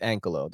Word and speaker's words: Ankylo: So it is the Ankylo. It's Ankylo. That's how you pronounce the name.